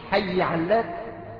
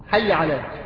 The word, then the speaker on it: حي على: تمشي حي علات؟ مكتوب هكذا هنا. حي علات؟ لا ما مش علات، أكيد مش علات. حي علات، لكن المشهور الحي، الحي هلة تنطق كيف؟ ها؟ حي على بتخفيف العين.